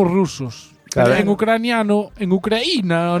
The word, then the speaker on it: Ucraína: Ucraína pronúnciase como cocaína.